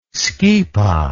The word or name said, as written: Schipa